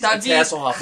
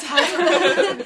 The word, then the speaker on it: Hasselhoff: How would you say his name in Spanish? – It's Hasselhoff everywhere. – It's Hasselhoff.